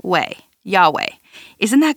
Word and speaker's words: Yahweh: I will be," and then he says that he is, in the NIV it says the Lord, but in Hebrew it says YHWH, pronounced Yahweh.